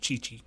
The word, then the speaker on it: Qiqi: No, it's just Q-I-Q-I. – Oh yeah, because the Q-U. Or the QI or whatever, or the Q just is pronounced as chi. Can't remember. Mm. Cha. – It's yeah. – Cha. – Yeah, and then it's just like, so just chi chi.